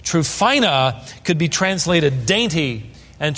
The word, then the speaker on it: Tryphena: Verse 12, Paul greets Tryphena, is how you would pronounce it, and Tryphosa.